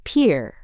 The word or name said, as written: pier